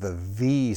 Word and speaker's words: Av: Now, this letter here, this second letter, Beit, can be pronounced with a V sound or with a B sound.